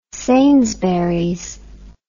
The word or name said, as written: sainsburys